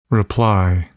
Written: reply

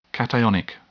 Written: cationic

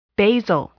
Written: basal

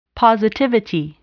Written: positivity

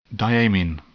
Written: diamine

